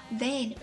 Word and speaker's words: vain: Vein, V-A-I-N, 一个字母之差，读音一样，意思却大不相同。